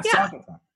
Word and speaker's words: Saugatuck: Is that right? – Saugatuck, isn't it? That's Saugatuck. How you say it. – Yeah. Saugatuck.